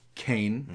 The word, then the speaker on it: Qayin: And his name is spelled Q-A-Y-I-N, but it's pronounced the same way as Kane.